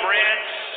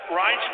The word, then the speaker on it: Reince: They call him Rance. Steve Wynn just called him. He's still not pronouncing it right, Steve. But Reince Priebus, now everybody pronounces his name right.